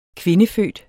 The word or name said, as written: kvindefødt